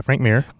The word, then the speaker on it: Frank Mir: It's Frank Mir, right? Is that how you say his name? – Yeah, Frank Mir.